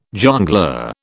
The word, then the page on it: jongleur: British English